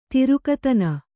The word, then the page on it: ತಿರುಕತನ: tirukatana